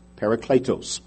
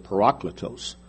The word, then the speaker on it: parakletos: You may have even the Greek word that this is brought from, which is a word parakletos, P-A-R-A-K-L-E-T-O-S, parakletos. I used to pronounce it parakletos, and then I talked to somebody who knew Greek, and they said parakletos.